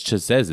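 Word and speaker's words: Chasez: It says Chavez. It's S in his name. It's Chazez, isn't it? – Chazez or Chavez? – It's C-H-A-S-E-Z. – Oh, okay then. Oh, Chazez. Yeah. Okay then.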